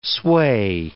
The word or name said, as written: sway